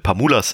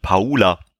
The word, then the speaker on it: Pamula: Und ich hatte durch einen Kumpel, der hat mir die Empfehlung gegeben, mal Patricia Pamulas Sachen anzugucken, wobei mir gesagt wurde, dass der Name gar nicht Pamula ausgesprochen wird, sondern Paula.